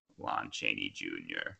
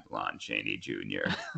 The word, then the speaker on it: Lon Chaney Jr.: Anyway, The Wolfman, starring Lon Chaney Jr. That's how I'm going to say his name every time, Lon Chaney Jr. As always, I'm joined by Mike and James.